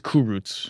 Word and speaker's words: Kurucs: What's the correct pronunciation? – I believe it's Kurucs. – Kurucs.